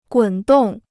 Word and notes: 滚动: gǔn dòng

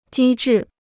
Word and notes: 机智: jī zhì

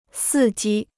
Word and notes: 伺机: sì jī

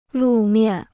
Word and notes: 路面: lù miàn

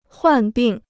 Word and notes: 患病: huàn bìng

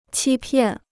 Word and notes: 欺骗: qī piàn

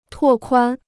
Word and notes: 拓宽: tuò kuān

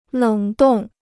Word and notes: 冷冻: lěng dòng

